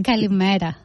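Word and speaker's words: Kalimera (Καλημέρα): Kalimera.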